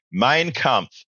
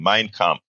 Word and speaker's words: Mein Kampf: Michael Radden says, Egberto, I'm so glad you repeatedly mispronounced that book. Hitler deserves no props, only insults. Well, I want to say it right. Mein Kampf. I want to be, I want to look like I know a little something.